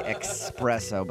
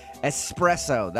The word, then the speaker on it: espresso: Oh, because they say it with an X. – Yeah, espresso. A lot of people say espresso, but there's no X. It's an S sound. The correct pronunciation, espresso.